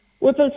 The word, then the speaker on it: Whipple: And just in relation to the treatment, Whipple surgery, what is that, if I've pronounced it correctly? – You have. Whipple surgery is at present the only life-saving surgery for pancreatic cancer.